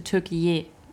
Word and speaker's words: Türkiye: Erdogan möchte jetzt nicht mehr Türkei, also ja, in Amerika und so, also international, sondern er möchte Türkiye ausgesprochen heißt es. Also auf türkisch heißt es.